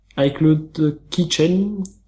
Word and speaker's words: iCloud Keychain: iCloud Keychain, j'espère qu'on prononce comme ça, Ouais.